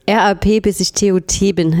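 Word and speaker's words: Rap bis (ich) tot bin: Antifuchs war das und ich habe natürlich den Titeltrack, den Tracktitel, den Titel des Tracks falsch ausgesprochen. RAP, bis ich TOT bin, heißt es natürlich richtig.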